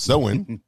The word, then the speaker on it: Samhain: Even though when you look at it, it looked like it would be spelled Samhain, but it is pronounced Samhain.